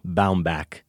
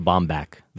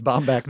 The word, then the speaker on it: Baumbach: I came in being sure it was Noah Baumbach, but we've got some other references here, and we're going with Baumbach.